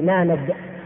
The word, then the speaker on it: نانق: هذا الرجل اسمه نانق أو نانق نانق نانق نون ألف نون قاف أحيانا يحط بدل القاف كاف عليها إشارة كذا يعني نانق نانق باللغة النطق الهندي نانق.